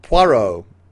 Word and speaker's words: Poirot: He is Poirot, or Porot, as it's spelled in here.